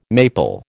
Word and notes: maple: American English

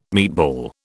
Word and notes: meatball: British English

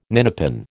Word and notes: ninepin: American English

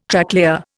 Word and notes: Tracklayer: Indian

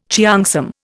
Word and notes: cheongsam: Indian

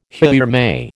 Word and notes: haere mai: American English

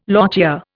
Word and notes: lochia: Indian

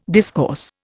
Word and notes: discourse: Indian

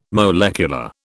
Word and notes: molecular: British English

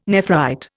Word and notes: nephrite: Indian

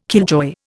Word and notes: killjoy: Indian

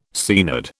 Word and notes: Seanad: British English

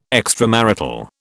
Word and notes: extramarital: British English

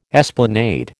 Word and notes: esplanade: American English

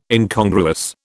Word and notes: Incongruous: British English

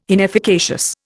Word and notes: inefficacious: Indian

